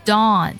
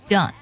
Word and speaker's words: dawn: 你看这个 dawn 在音长上呢，它会比第一个 dawn 要长很多，是不是？但在口型上呢，它又没有 ow 这样的一个变化。